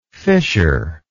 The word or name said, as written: fissureless